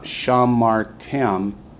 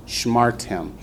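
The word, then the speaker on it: shmartem (שמרתם): It's not shamartem, it's shmartem. Notice the shiva under the sheen makes it a short syllable. Shmartem. It's to keep us from accenting anything but the last syllable.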